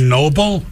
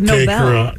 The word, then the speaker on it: Nobel: But I always thought his name was Alfred Nobel. – Yeah, it is. – Yeah. – It's the Nobel Prize.